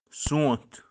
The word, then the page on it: sonth: Kashmiri